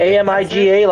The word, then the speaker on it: Amiga: Yeah, Amiga. – AMIGA, like the old computer? – Yeah.